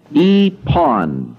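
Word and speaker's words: epon (εἶπον): That's pronounced, number one, the past tense of the first person, I, epon, E-P-O-N, epon, I said.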